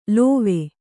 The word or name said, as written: ಲಾವ